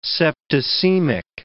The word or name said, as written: septicemic